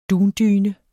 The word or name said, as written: dundyne